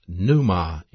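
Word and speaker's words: pneuma (πνεῦμα): A body, pronounced soma in the Greek, a spirit, pronounced pneuma in the Greek, and a soul, pronounced psuche in the Greek.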